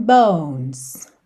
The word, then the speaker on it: bones: Oh, yeah, your skin and bones. Bones. Não pronuncia a letra E, tá bem?